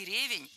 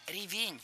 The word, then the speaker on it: ревень: Начальная форма не ревень, а ревень. Ударение в этом слове падает на второй слог.